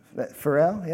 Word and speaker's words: Pharrell: I'm sure you've heard the song by, I think, how do you say it, Pharrell? Pharrell, yeah.